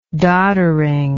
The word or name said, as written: dodderiness